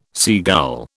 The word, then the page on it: seagull: British English